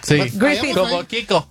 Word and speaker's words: Griffith: Cada vez que ponga la TH como the, como THE. – A ver, Griffith. ¿Cómo se dice, pues? – Griffith.